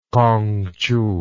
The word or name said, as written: Kongqiu